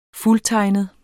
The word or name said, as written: fuldtegnet